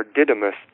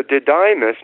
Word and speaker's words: Didymus: Thomas, or Didymus, Didymus, I believe is how they pronounce it. And it's a Greek word, and that di at the beginning, D-I, is the same, like a dipole in physics or chemistry, or a diatoms, you know, little atoms that have two, or little organisms that have two molecules to them.